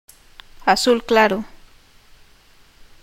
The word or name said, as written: azul claro